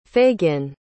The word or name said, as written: Fagin